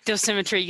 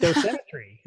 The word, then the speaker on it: dosimetry: You're going to have to pronounce that word for me, Bevin. What's the word? Dosimetry? – Dosimetry, yes. – Dosimetry. Okay. – You got it.